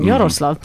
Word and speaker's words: Jarosław: That's right. It's Polish pronunciation. Jarosław.